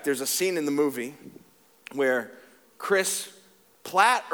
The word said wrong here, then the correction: Pratt: or Pratt, does anybody know? Pratt, P-R. See, I said it wrong earlier, and I think I'm gonna get an email just for saying the wrong name, but whatever, that's fine.